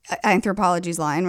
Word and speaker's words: Anthropologie: Anthropologie's line, right? – Oh, shit. – Am I saying that right? – Yeah. No, you are.